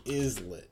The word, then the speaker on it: islet: I've always thought it was like islet. Like you don't pronounce the S in island. It's like the same thing. – How do you pronounce islet?